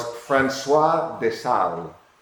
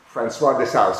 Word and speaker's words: François de Sales: I grew up calling him St. Francis de Sales, but that's not the correct pronunciation. Maybe that's the American way. Here in the Philippines, he's called St. Francis de Sales. But he himself, because he's French, was François de Sales.